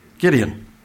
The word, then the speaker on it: Gideon: You can remember who the prophet was that fought against Midian because his name rhymes with Midian. It's Gideon.